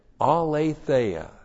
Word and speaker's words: aletheia: It's the result of walking in aletheia.